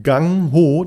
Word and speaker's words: Gung Ho: Und zwar ähm, geht es um äh, das Buch, äh, es wird äh, ausgesprochen Gang Ho, das wird aber geschrieben äh, mit G-U-N-G und dann H-O.